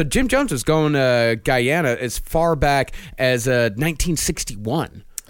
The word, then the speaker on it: Guyana: It's actually pronounced Guyana, Guyana.